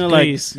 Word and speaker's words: Cris: It's spelled C-R-I-S.